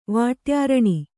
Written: ವಾಟ್ಯಾರಣಿ

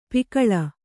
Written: ಪಕಳಿ

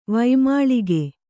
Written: ವೈಮಾಳಿಗೆ

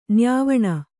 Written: ನ್ಯಾವಣ

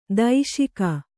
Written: ದೂಷಿಕೆ

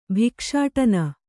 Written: ಭಿಕ್ಷಾಟನ

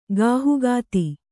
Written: ಗಾಹುಗಾತಿ

